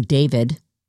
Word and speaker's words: D4VD: I'm going to give you an update on David, who is spelled D4VD.